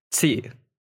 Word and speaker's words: qi: And that annoys me, because as someone who is able to speak Chinese, I know how it's actually pronounced, which is qi.